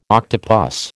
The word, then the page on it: octopus: American English